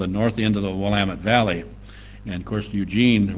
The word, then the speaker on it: Willamette: But I live in the north end of the Willamette Valley, pronounced Willamette.